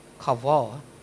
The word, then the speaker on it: kavah: The word wait here is the Hebrew word kavah. Kavah. Q-U-V-A-H. Now you pronounce that. It's Hebrew. Alright? The pronunciation is K-A-W-V-A-W.